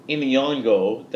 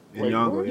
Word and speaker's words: Nyong'o: We got an Academy Award winner, Lupita Nyong'o. Did I say that wrong? – Nyong'o, you tried.